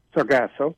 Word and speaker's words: Sargasso: the synopsis for After the End, less than a year after ghosts aboard a derelict ship floating in the Sargasso Sea. I'm mispronouncing that, aren't I? Sargasso? – Sargasso, but Sar- that's fine. Okay.